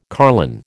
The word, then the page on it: carline: American English